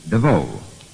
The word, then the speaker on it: Daveau: DeVoe is spelled D-A-V-E-A-U, DeVoe.